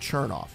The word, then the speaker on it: Chernoff: And if you want to save 10% at checkout, use my last name, which is your promo code Chernoff.